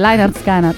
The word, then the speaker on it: Lynyrd Skynyrd: Ma Lynyrd Skynyrd, l'ho detto bene. – Grande, Lynyrd Skynyrd.